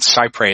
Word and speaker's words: cy pres: Now, the doctrine called CyPres, and that's spelled C-Y-P-R-E-S, and it comes from that's kind of an anglicization of a French, part of a French phrase that means as close as possible.